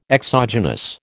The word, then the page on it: exogenous: American English